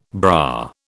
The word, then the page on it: bra: British English